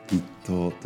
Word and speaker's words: 立冬: November was the start of 立冬立つ冬と書いて立冬と読みます